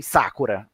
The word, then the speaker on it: Sakura: Não tem Sakura, era Sakura, se fosse para se é a pronúncia correta, porque as, é, o, a maioria é oxítona, ou seja, a tônica é a última sílaba, sakura, que no caso é como é a pronúncia corretamente em, em japonês, ou às vezes paroxítona, que é a, a penúltima sílaba.